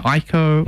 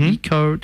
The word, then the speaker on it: Ico: It's coming from the studio behind, Shadow of the Colossus and Ico, mm-hmm. or Ico, depending on how you pronounce it.